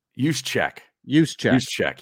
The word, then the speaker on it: Juszczyk: Jusic. – Yeah. Is that how you, that's not how you pronounce it, is it? – I thought so. – Juszczyk.